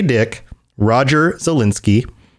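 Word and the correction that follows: Zelazny: Roger Zelezny, right? That's how you pronounce it. – I think it's Zelezny. I've always said Zelezny. I who knows? – Yeah, Zelezny. John knows.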